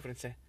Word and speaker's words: français: Français is written with an S at the end. – I know that. – And you do not pronounce it.